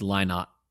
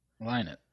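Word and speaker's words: Lynott: But I mean, when you think of Thin Lizzy, you think of Phil Linnet, and I'm sure we're saying that incorrectly. Linnet. I don't know if that's right. Hold on, let me look up. Yeah, you're right. It's Linnet. Linnet. – Linnet.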